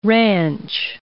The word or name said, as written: ranch